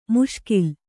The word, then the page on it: ಮುಷ್ಕೀಲು: muṣkīlu; Kannada